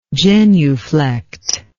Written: genuflector

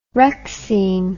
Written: rexine

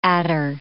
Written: adder

